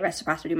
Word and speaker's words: reciprocity: is it reciprocity with each other? Is that how you say it? – Reciprocity. Yeah.